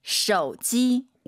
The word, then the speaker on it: 手机: 쇼지 쇼지 아니죠? – 手机， 오유는 어예요. 쇼지 기억하시면 좋겠습니다. 해도 된다.